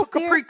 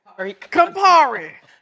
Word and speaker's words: Campari: Let me sip my no, Capri. – What, how you say it now? Campari.